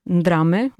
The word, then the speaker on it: ndrame: J'ai choisi ce mot, ndrame, on le prononce comme ça dans mon dialecte, qui veut dire entrailles.